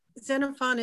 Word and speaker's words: Xenophon: Xenophon, Z-E-N-A-P-H-O-N, I think, Xenophon. Where knowledge and... – Hello. – Yeah. – Xenophon is X-E-N-O-P-H-O-N.